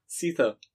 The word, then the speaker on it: Seether: S-E-E-T-H-E-R. – Ach, so war kein T-H, ne? Ach, Cether. Ja, siehst du? Cether.